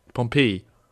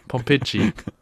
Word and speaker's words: Pompeji: Pompeji. Pompeji. Pompeji.